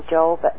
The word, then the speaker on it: Job: And even sent Satan down, because at that time Satan worked for God, you'll notice, and made the life of Job a misery. – Are we talking Job, the one that we pronounce? – Yeah, Job is Job. That's where Job comes from.